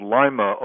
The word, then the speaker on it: Lima: I was raised in a town in the northwest called Lima, Ohio, spelled like the Lima bean.